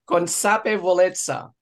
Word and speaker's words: consapevolezza: It rhymes with pizza, consapevolezza.